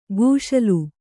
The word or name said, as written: ಕುಶಾಲು